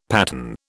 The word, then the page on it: patten: British English